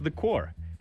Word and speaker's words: The Corps: So the band is spelled The Corps. Apparently it is pronounced The Core. So it's such a massive waste of P and S in the word. It's like 2/5. It's like 40% of this word is not pronounced.